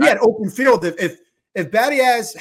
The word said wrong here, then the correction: Biadasz: that's how you say it, right? – Biadasz. – Biadasz.